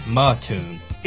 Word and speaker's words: Mattoon: Is it Mattoon or Mattoon? – It's Mattoon, dummy.